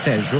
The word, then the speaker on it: Tello: l'argentino Facundo Tello, anzi Tejo, considerato che in Sud America la doppia L non si legge Ye ma si legge Sh,